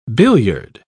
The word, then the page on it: billiard: British English